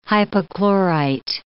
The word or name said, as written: hypochlorite